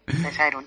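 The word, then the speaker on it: Caesareum: Creo que lo he dicho bien, buenas tardes. – Hola, buenas tardes. Sí, sí, lo he dicho bien. Caeserum, sí. Así es.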